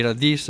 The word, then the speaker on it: Radìs: Ricordiamo anche appunto che oltre al sito, volendo, ci trovate anche su Facebook, basta scrivere Radis con l'accento sulla is.